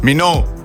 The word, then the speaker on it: Minogue: A znaš izgovoriti njen primek? – Ja, znam. Minogue.